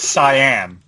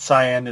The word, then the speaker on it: Cyan: It's Cyan. – Cyan? – Yes. Yeah, like Scion is the type of car.